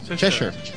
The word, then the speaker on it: Cheshire: Cheshire.